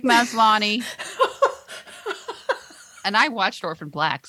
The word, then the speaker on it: Maslany: How do you say her name? – Maslany. – I think it's Tatiana Maslany. – Maslany. – You're right.